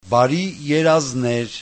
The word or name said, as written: barierazner